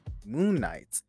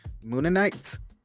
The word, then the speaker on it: Mooninites: There were LED powered light up signs depicting moon nights Moon. Hold on. I think I said that incorrectly. Mooninites. Oh, I hope that's how you say it.